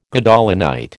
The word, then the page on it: Gadolinite: American English